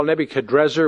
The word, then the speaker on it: Nebuchadrezzar: Well, first of all, Nebuchadrezzar, which your Bible probably has, is actually probably the more accurate pronunciation, but we're also used to the Nebuchadnezzar, which the Hebraized transliteration leads us to.